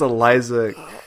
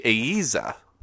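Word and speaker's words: Eiza: Oh, it's Iza. There's no L. I guess if you're using the Spanish pronunciation, it would be Aiza.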